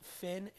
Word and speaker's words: fin: Finn, and I say L. Finn, I understand that Finn is pronounced in French, pho, and in Portuguese and in Spanish as fin, El fin.